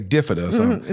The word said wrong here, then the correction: bifida: It's a bifida.